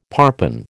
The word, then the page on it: parpen: American English